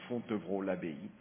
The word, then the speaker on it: Fontevraud-l'Abbaye: Incidentally, Saumur is the town nearby which my wife and I have been living since 2014 in this village, which Pastor Heiberg pronounced so nicely, Fontevraud-l'Abbaye. And this is in the Anjou region in France, by the beautiful Loire River.